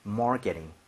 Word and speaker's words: marketing: R 발음 살린단 말은 혀를 많이 뒤로 당겨달라는 얘기입니다. 입모양 좀 모아주시고요. Marketing.